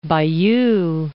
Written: Bayeux

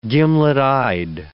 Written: gimlet-eyed

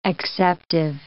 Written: acceptive

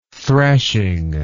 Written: thrashing

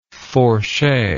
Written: fourche

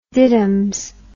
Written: diddums

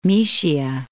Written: Moesia

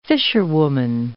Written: fisherwoman

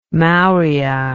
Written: maurya

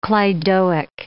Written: cleidoic

